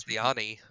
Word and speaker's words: Castellani: I think it's, Castellani.